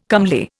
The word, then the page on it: comely: Indian